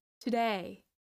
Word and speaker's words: today: But it's important to keep in mind that the first syllable in to, to is a schwa sound. The stress is in the second syllable. Today, today, today. So the first sound is a very fast schwa sound, t, and the long syllable is the last one, the one about day.